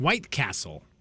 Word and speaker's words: White Castle: I mean, that's not how it's said. I mean, how do you say it? – White Castle. – What did I say? – White Castle. – I did emphasis on the wrong word.